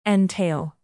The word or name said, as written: entail